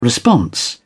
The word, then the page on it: response: British English